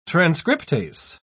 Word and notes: transcriptase: British English

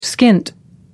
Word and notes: skint: American English

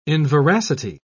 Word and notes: inveracity: British English

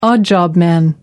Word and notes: odd job man: American English

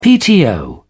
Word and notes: pto: British English

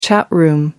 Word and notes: chat room: American English

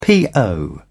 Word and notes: po: British English

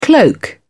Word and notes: cloak: British English

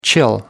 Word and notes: chill: American English

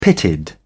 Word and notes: pitted: British English

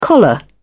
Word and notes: collar: British English

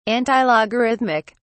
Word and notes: antilogarithmic: British English

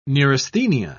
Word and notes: neurasthenia: British English

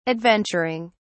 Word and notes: adventuring: British English

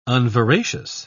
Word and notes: unveracious: British English

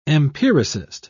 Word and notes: empiricist: British English

